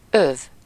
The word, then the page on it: belt: American English; /bɛlt/